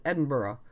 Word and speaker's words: Edinburgh: From what I found, more Scots don't do burra. It's more like bruh. It's more one syllable, and it can be two syllables, but they're again short syllables. So it's Edinburgh or Edinburgh. – All right. – It does not rhyme with Pittsburgh.